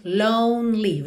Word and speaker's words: lonely: Lonely, atenção, mais um E que não é pronunciado, tá bem? Então, não é lonely, mas lonely. Você pensa de ir do N direto pro L, lonely.